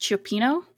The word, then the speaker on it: cioppino: Yeah, I just looked up, I think it's pronounced cioppino, which is like a soup with mussels and clams, and it's lovely and delicious.